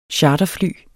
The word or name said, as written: charterfly